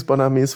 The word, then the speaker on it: Bonames: Aber man spricht es Bonames wohl aus.